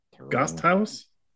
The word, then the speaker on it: Gasthaus: That at a nearby Gasthaus, or however you pronounce that, Gasthaus? – Yeah. – That sounds more German.